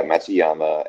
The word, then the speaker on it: Matsuyama: Mitsuyama. – Yeah, Matsuyama, Adam Scott, and Sungjae Im are probably the most popular or well-known internationals.